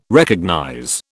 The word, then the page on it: recognize: British English